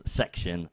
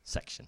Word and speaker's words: 6TION: And the next one is called Section, and it's spelled 6TION. – What, sorry, 6TION. – Section.